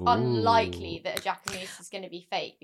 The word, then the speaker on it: Jacquemus: I think the Jacquemus are real.